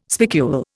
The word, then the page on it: spicule: Indian